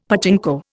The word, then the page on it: pachinko: Indian